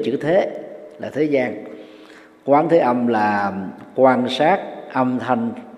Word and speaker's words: Quán Thế Âm: Vào Đề Tào Quỷ, dịch giả Phan Tăng Khải trong Vô Lượng Thọ Kinh dịch là Quán Thế Âm, hoặc có thể đọc là Quang Thế Âm, vì cái chữ Hán đó, quang với quán đó, có hai cách đọc, đọc cách nào cũng được xem là đúng.